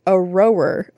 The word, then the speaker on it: Aroer: You're trying to say it right here. – Error. – See? Dino roar. A rower. A row. A-R-O-E-R. – A rower. – See? I told you. I said it right the first time.